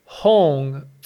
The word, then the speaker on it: Hong: The sacred mantra and vibrational healing sound for the third energy chakra is Hong, spelled H-O-N-G, pronounced Hong.